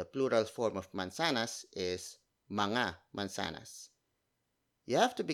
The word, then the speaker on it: mga: Mga, spelled as M-G-A, it's a contraction of the word mga, which is spelled as M-A-N-G-A, and then you add it at the beginning of the word that you want to pluralize.